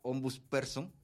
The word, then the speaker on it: ombudsperson: O sea, precisamente por temas como la ombudsperson, lo dije bien.